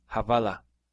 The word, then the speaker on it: hawala: In Farsi, Iran, the W is pronounced as a V, havala.